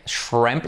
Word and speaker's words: shrimp: La I como una E, shrimp, la R fuerte, paper sister y la SH bien fuerte.